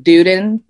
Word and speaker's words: Duden: We're going with Duden because Duden.